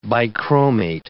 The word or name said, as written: bichromate